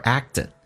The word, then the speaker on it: act: 来看一下 have you 不被强调，第一个 have 说成 have， you 可以说成也，也可以说成 you，用降调来发 have you， have you ever， have you ever acted。这个单词听力有点难点，第一个是那个可你听不到，美式发音那个可不发音，两个辅音在一起就用发后面一个，而且气流要阻塞一下，叫 act，而且 t 要发成 d 浊化， act 表现的。